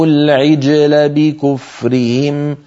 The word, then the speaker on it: العجل: العجلة، جيم ساكنة قبلها مكسور بعدها مفتوح، علينا أن ننطق هذا الجيم غير متأثرة، لا بالكسرة قبلها ولا بالفتحة بعدها، هكذا. العجلة، عجلة. وأشربوا في قلوبهم العجل بكفرهم، العجلة.